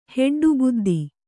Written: ಹೆಡ್ಡುಬುದ್ದಿ

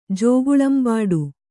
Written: ಜೋಗುಳಂಬಾಡು

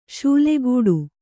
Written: ಶೂಲೆಗೂಡು

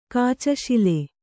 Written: ಕಾಚಶಿಲೆ

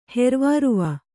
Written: ಹೆರ್ವಾರುವ

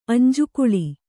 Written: ಅಂಜುಕುಳಿ

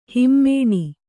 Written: ಹಿಮಾನಿ

